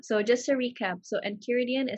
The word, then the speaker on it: Enchiridion: Enchiridion. – Yes, Enchiridion.